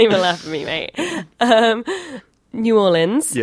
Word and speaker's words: New Orleans: So Ryan, you live in New Orleans.